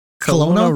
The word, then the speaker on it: Kelowna: Kelowna Rockets? – Yeah.